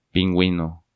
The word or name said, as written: Pinguino